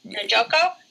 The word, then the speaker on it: Njoku: Njoku, How do you say it? Njoku. – Njoku. Yeah.